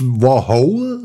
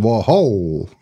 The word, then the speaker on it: Warhol: Nicht Warhol, sondern Warhol. Sag ich ja ein tschechischer Name, Warhol.